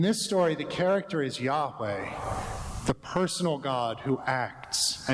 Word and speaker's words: Yahweh: That sacred group of Hebrew letters that our hymn pronounces Jehovah, but we usually say Yahweh.